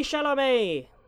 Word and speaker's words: Chalamet: Starring Timothée Chalamet.